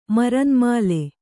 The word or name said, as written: ಮರುನ್ಮಾಲೆ